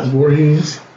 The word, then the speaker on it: Voorhees: Voorhees. – Oh, that's how you pronounce that name? – Yes.